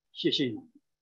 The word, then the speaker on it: 谢谢你: 谢谢你。谢谢你。 – That's it. – Thank you. – Thank you. 谢谢你。